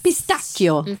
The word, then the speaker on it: pistacchio: Pistacchio. Pistacchi is the plural. – Oh. – Yes, it is. We've taken the Italian word pistachio, but we are pronouncing it wrong with a soft C.